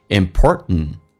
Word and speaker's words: important: 那么经常我们会看到口语化当中 important 的第一个 t 不发音，弱化一下 important，就好像 kitten 说成 kitten，forgotten 说 forgotten。 T 跟 N 在一起的时候经常 T 不发音，那么你可以发也可以不发都对。